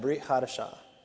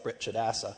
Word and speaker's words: Brit Hadashah: And this one is the funniest of all, Brit Hadashah, because it's kind of hard to say. I mean, especially if you read it as it looks like it's spelled in transliteration, it looks like Brit Chadassah.